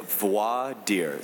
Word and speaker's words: Voir Dire: You pronounce it Voir Dire. So it's a French word it's like voila. It's the same root. It's like to see, like see, voila.